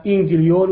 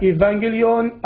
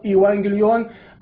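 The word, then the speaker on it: إيفانجليون (εὐαγγέλιον): وبعيدا عن أنه عندما تحدث عن كلمة إنجيل باليونانية كتبها خطأ ونطقها خطأ، فكتبها وحذف منها حرف نو اليوناني، وعندما نطقها نطقها إنجليون وهذا خطأ، فهي تنطق إما إيفانجليون أو إيوانجليون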